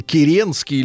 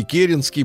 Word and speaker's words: Керенский: Итак, друзья мои, талантливый руководитель Керенский или Керенский, по-разному э, ударение ставят в этой фамилии, э, до конца своих дней, до э, конца 60-х годов э, считал себя законным правителем э, России, бодрился, давал интервью.